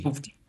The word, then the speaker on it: PUBG: No se dice Puff, se dice PUBG. – Pupchi.